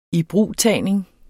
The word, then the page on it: ibrugtagning: [ ˈiˈbʁuˀˌtæˀjneŋ ]; [ -ˌtæːjneŋ ]